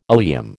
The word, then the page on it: oleum: American English